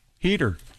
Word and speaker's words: Heder: Heater. – Is it Heater? – I always heard Heater too.